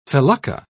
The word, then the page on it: felucca: British English